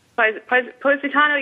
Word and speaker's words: Positano: It's not Positano. Am I saying that right? Positano, yeah, you don't have to take Positano, a boat.